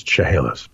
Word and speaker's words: Chehalis: Chehalis. I was right. It is Chehalis.